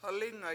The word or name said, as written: halIInga